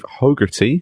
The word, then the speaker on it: Hogarty: Oh, Steve Hogarty is the way my name is pronounced.